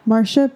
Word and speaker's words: Marsha: So today, the woman I chose to speak about and to represent is Marcy... Oh my God, I already said her name wrong. Marsha P.